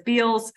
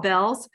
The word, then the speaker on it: Bealls: It's spelled B-E-A-L-L-S. Sometimes people mispronounce it as Beals and pronounce it phonetically, but it's called Bell's.